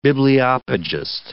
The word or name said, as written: bibliopegist